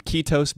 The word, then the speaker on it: Kiitos: And this is from Ketos Brewing, and it's spelled K-I-I-T-O-S.